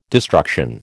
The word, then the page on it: destruction: American English